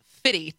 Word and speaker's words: 50 Cent: And my then co-host corrected me and said, no, it's Fitty.